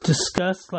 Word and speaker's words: Disqus: DISQUS. That is pronounced discuss, like to discuss something.